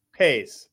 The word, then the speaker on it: Pais: Pais. – Sonny Pais